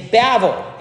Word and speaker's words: Babel: The name Babel is Babel in Hebrew.